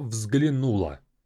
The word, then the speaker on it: взглянула: Взглянуть, even though it has four consonants in the beginning, is a difficult thing to pronounce, but try to say it after me. взглянула. Взглянула. So взгл is the most difficult part.